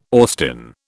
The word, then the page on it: Austin: British English